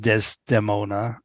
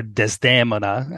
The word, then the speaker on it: Desdemona: And then after the intermission, we got the entire fourth act of Verdi's opera Otello, with Angel Blue as Desdemona, or Desdemona, as it's pronounced in the opera, and Russell Thomas as Otello, and a few other singers in smaller roles.